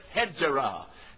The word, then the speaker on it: Hegira: And that is called the Hegira, or as they pronounce it, Hegira.